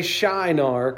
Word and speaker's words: Shinar: And so you say Shinar, kind of like the word shy, nar.